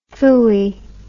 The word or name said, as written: fooey